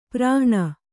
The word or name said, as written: ಪರಾಹ್ಣ